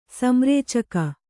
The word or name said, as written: ಸಂರೇಚಕ